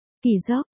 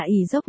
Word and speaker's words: kỳ dốc: Kỳ dốc, đây là một kế trong binh pháp mọi người hay được nghe hoặc đọc là ỷ dốc, nhưng nguyên bản nó phải là kỳ dốc, chỉ hành động tách binh lực ra làm hai phía để kìm hãm quân địch hoặc để chi viện cho quân mình.